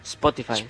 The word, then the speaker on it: Spotify: Spotify. Muy bien esa pronunciación. Spotify. – Pero nosotros le clavamos Spotify. – Claro, Spotify, con ese bien fuerte, como corresponde.